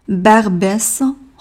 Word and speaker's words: Barbès: 首先是 barbès rochechouart，barbès 专有名词，s 发音比较特殊。接下来是一个比较长的 rochechouart，o u。跟后面的哇形成了半元音乌啊哇，所以是 ho sh shua ho sh shua ho sh shua 连起来 barbès ho sh shua barbès ho sh shua 最后一个站名 porte d'Orléans 要注意的点，两个大口的 o porte Orléans，其次。